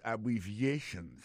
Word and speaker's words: abbreviations: En parlant de accent, accent tonique qui vient pour nous prononcer mot yo, n'a pas un mot abbreviations nan qui signifie abréviation. Abbreviations, accent tonique là, tombé sous troisième syllabe là.